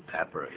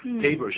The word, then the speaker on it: pepper: 就是 pepper，也就是胡椒粉，可不要念成 paper，paper、 paper 是纸张啊，那就完全不一样了。